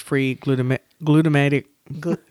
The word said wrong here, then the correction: glutamic: glutamic glutamic acid.